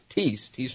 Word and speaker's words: Thies: It's Tease Farms, T-H-I-E-S, but it's pronounced Tease Farms.